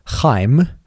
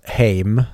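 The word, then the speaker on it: Haim: Mm, hon är ju en av tre då, tillsammans med sina två andra systrar i gruppen Chaim eller eh, jag antar att det uttalas Heim, mm.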